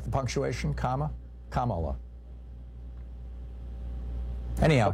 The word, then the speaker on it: Kamala: Kamala, I've heard. – They mispronounce it on Fox News, and Trump mispronounces it as a way of slurring her. It's Kamala.